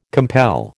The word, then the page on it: compel: American English